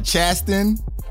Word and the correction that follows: chastened: That is the wrong pronunciation, but it is the correct word. The word is chastened. Chastened. It does look like Chasten.